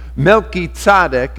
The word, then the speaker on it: Melchizedek: Melchizedek is how you say it in Hebrew.